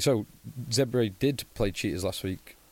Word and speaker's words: Zebre: Then Southern Kings host Zebre. This will be a good leveller. – Yeah. – Zebre. Apparently we're saying it wrong. It's Zebre.